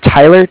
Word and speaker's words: Tyler: Yeah, my name's Tyler, T-Y-L-E-R, and I'm calling with Cardiff. It's spelled C-A-R-D-I-F-F, as in Frank, Frank.